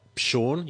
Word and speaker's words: Pshorn: Verena Pshorn, you do pronounce the P apparently.